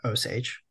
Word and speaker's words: Osage: And uh, the French would spell that O U S A G E, and the English would pronounce that Osage. So that's how we go from Wajaji to Osage.